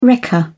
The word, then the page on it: wrecker: British English